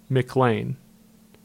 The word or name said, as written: McLane